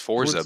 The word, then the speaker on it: Forza: Z sounds like the T. – Forza. It's just Forza, big guy.